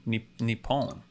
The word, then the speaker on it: Nippon: Nihon, N-I-H-O-N. Or Nippon is sort of the more familiar. – The Japanese name for Japan can be pronounced either Nihon or Nippon.